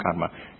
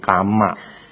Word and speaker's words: karma: Hukum karma juga dibaca hukum karma, jangan dibaca hukum kama.